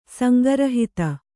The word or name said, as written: ಸಂಗೃಹೀತ